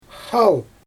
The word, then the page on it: xaal: Xyzyl